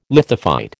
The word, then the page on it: lithophyte: American English